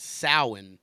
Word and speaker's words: Samhain: and that Samhain word is pronounced Samhain.